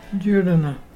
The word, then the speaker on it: jurruna: our local Mulgana word today is Jurdana. Jurdana is pelican, and to spell it is J-U-R-R-U-N-A. Can you say Jurdana?